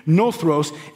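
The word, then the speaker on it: Nothros (νωθρός): In the Greek, it's actually the word nothros. I just forgot how to pronounce it for a minute. Nothros.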